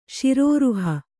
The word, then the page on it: ಶಿರೋರುಹ: kannada